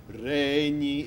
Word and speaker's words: regni: That never happens in Italian. Okay. It just never ever happens. Regni, it's always, you must give at least 2/3 to the stress syllable.